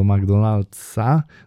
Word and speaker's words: McDonald's: W McDonald's jest na końcu S, a z jakiegoś powodu często zdarza się tak, że Polacy tego nie wymawiają. Wydaje mi się, że to jest po prostu bardziej dla nas naturalne. Natomiast ten wykładowca wtedy zwrócił nam uwagę na to, że powinniśmy mówić, że idziemy do McDonald'sa, no bo przecież jakże to tak opuścić to S na końcu.